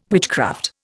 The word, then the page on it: witchcraft: Indian